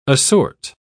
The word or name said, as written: assort